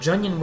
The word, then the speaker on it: Junon: But in the beginning, you can just totally pass it up and go to, I don't know how to pronounce it. I'm going to say Junon. J-U-N-O-N. – Okay. – Or Junon. I guess we'll find out. I say Junon. – Mako. – Because Junon sounds weird.